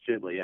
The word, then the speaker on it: Ghibli: Is that the correct pronunciation? – I'm pretty sure it's Ghibli, yeah.